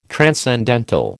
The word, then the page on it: transcendental: American English